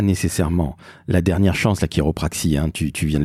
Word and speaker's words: chiropraxie: Il faut savoir écrire et surtout lire chiropraxie parce que tu l'as dit, ça s'écrit C-H-I-R-O-P. R-A-X-I-E. Ça s'écrit chiropraxie et ça se prononce chiropraxie.